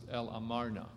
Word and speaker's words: El Amarna: But if you travel all the way down, the last thing you'll see here is El Amama, and most of the time pronounced El Amarna.